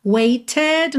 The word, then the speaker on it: waited: Então, nesses dois, waited, nós temos rarara no T, waited. Claro que se você quiser, pode cantar how long I have waited. Dá até um pouco mais de ênfase para essa espera, né? Mas, Diana Krall, por exemplo, faz rarara nesses T's, tá?